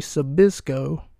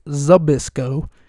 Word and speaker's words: Zbysko: and I played Larry Zbysko. Sorry, I pronounced his name wrong. It's Zbysko, and he played someone else.